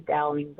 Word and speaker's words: Dowling: On Instagram is my name, Nikita Dowling, but it's spelled like Chiquita Banana almost.